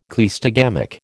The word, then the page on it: cleistogamic: American English